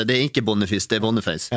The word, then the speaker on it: Boniface: Vi fick ju också besked av vi var I Molde på kamp där så kom OI bort och bara Han från Nigeria, där uttalas det Boniface.